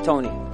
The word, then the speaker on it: Tony: S-U-H-A-I-L-A. – And your name, sir? – My name is Tony. – Tony, can you spell that for me? – T-O-N-Y.